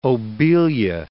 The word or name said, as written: obelia